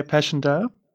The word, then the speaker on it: Passchendaele: The next question is going to be the Battle of Passchendaele. I'm not sure that I'm pronouncing correctly, but I think you know what I'm talking about. World War One, 1917, Passchendaele. – Yes, yeah, Passchendaele.